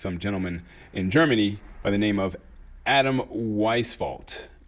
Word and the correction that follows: Weishaupt: I might be totally pronouncing that wrong. It's Weishaupt. I've written down.